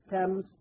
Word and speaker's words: Thames: How do you say that? Thames in England.